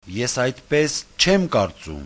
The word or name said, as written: esaydchemkartsum